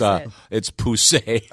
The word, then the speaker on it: poussé: It's not pronounced PUS. – It's pronounced. – Oh, my God. – It's a long no, I was looking at apparently it's French and it's poussé.